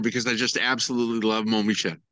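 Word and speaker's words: Mont-Saint-Michel: So I visited, Saint Mont-Michel. Not pronouncing that correctly. You think a Canadian could speak French, but right. Oh gosh. – Mont-Michel. – Mont-Michel. Yes. Thank you.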